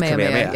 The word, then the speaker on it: Kamehameha: Kamehameha, I think it's supposed to be pronounced Kamehameha. – Yeah, it's supposed to be pronounced Kamehameha.